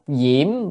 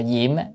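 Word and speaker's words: diễm (焰): Độc diễm biến địa, chữ này đọc là diễm à, diễm có nghĩa là lửa, có khi đọc là diệm, diệm nhiên vương Bồ Tát hay diễm nhiên vương Bồ Tát á, có khi đọc diệm, đọc âm nào cũng được,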